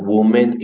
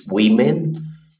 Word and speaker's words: women: The plural is not woman, it's women.